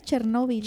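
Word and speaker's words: Chernóbil: En español Chernobyl. – Chernobyl. – Tiene acento en la O.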